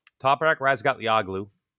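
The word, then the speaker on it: Toprak Razgatlıoğlu: Toprak Razgatlioglu,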